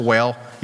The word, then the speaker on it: whale: We don't know that it's a whale, even though it's overwhelming mercy. You ever know the old guys, they really pronounce the H when it's like whale. They say a whale, wheat thins.